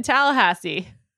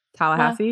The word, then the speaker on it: Tallahassee: Yes, the same location in Tallahassee. – Wow, the way you said Tallahassee was weird. – How do you say it? – I feel like the emphasis was in the wrong spot. Tallahassee?